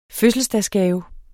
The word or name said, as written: fødselsdagsgave